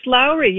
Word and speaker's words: Lourey: Is it Lourey? Lourey. – It's Lourey. You pronounce it perfectly.